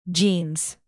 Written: jeans

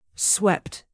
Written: swept

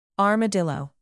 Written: armadillo

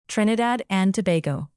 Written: trinidad

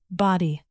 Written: body

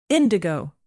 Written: indigo

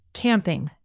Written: camping

